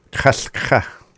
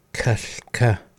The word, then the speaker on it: Kaska: And then this is how you say raspberry. Kaska. – Kaska.